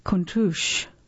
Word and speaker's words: Countach: Okay, before we move on, I must correct my pronunciation of the car. It's Lamborghini Countach.